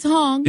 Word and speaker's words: Hong: That was correct. Mrs. Hong. – Yes, it was. – H-O-N-G. Very good.